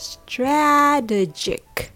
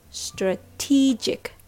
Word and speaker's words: strategic: Just remember that the word strategic has the stress on T instead of stra. So it's strategic. It's not strategic. It's strategic. Strategic.